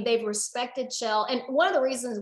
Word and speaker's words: Kjell: So, and what's really neat is, so Kjell, my new husband, spelled K-J-E-L-L, it's Norwegian, but he grew up in Idaho, pronounced Kjell.